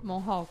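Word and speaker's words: Mohawk: É isso que se pronuncia, Alice Lessa, tá certo? Monhawk.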